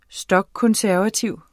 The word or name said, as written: stokkonservativ